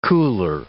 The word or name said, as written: cooler